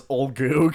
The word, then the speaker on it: Ulgu: The only one we got right off the bat was Olgu, because it's like U-L-G-U, and we were like, oh, Olgu.